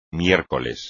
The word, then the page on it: miércoles: Spanish